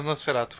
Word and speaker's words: Nosferatu: Mas ele lembra do Nosferato sabe? Tipo, esse ah, negócio eu falei necromante, é né, Nosferatu, falei errado.